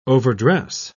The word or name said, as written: overdress